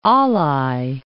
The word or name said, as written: Alai